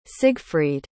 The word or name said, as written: siegfried